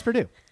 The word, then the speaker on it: Purdue: even though they are pronounced the same way. It's just Purdue.